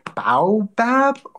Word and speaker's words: baobab: But then in the evening, I use their baobab oil. I don't know how you pronounce baobab. – It. – Baobab. Yeah, baobab. – B-A-O-B-A-B.